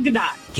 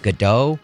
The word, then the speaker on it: Gadot: Is it I used to call it Gadot. I thought it was Gadot for the longest time, but now it's apparently Gadot.